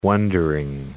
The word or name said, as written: wonder-worker